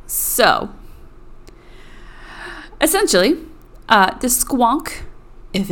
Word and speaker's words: Squonk: If anyone wants to know, it is spelled S-Q-U-O-N-K.